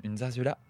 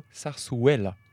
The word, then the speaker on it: zarzuela: Alors, je tiens à dire d'abord que nous avons toutes mal prononcé parce que les deux Z se prononcent S et le U se prononce W. Donc on dirait plutôt zarzuela.